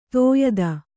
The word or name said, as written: ದಾಯಾದಿ